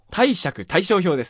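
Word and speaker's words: 貸借対照表: あとですね、賃借対象法表？ – 貸借対象表ですね。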